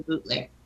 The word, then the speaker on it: Boutelier: Hey, so is your last name, is that pronounced Ashlyn or is it Boutelier? – My business is called Mary's Ashlyn, and Ashlyn means look into the future. But my last name is really Boutelier.